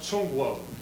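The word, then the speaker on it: Zhongguo (中国): And it's pronounced Zhongguo. Guo is country, Zhong is the middle.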